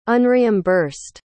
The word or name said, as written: unreimbursed